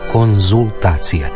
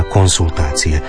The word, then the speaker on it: konzultacije: A evo slučaja u kojem se često izgovara i piše s, a trebalo bi se reći z. I doktorandi i studenti prije obrane doktorata ili prije ispita idu svojim profesorima na konzultacije. Ne idu na konsultacije.